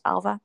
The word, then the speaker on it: Ailbhe: So you will see most of me on TikTok, which is just my full name, at Alva Cook. And that is spelled A-I-L-B-H-E. For everybody who doesn't know how to pronounce my name, it's pronounced Alva. And I am the same on Instagram, Alva Cook, with three E's on the end of Cook.